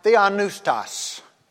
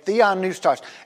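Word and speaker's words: theopneustos: And so the phrase given by inspiration of God, the Greek word is pronounced something like theonoustos. Okay, theonoustos. It's a compound word, and you see there, first of all, God, theos, God, and then breathe.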